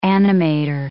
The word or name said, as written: animator